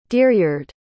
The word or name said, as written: deeryard